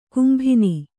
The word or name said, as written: ಕೆಂಬೊನ್